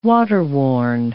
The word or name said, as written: waterworn